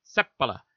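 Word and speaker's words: Seppälä: I played 1 year here with the Edmonton Oil Kings and asked him how you pronounce your name, because I've always been told Finnish names, accents on the first syllable, and you kind of say it quickly after that. So it was Seppala.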